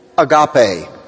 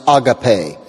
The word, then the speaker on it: agape: In the next chapter, in chapter 20, he talks about what he calls and what the church is called agape, or you may have heard it pronounced agape.